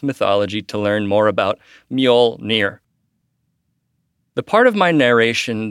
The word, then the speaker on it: Mjolnir: I was saying Mjolnir, and it's actually Mjolnir.